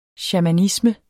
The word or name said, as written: shamanisme